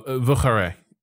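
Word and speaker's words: Vieux Carré: It's spelled like view, but like Frenchify it, V-I-E-U-X. Carre, C A R R E with an E with an accent.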